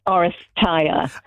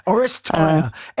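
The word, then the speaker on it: Oresteia: Orestia? – Well, close. I think there's a UK and a US pronunciation. I'm going to say Orestia. – Orestia.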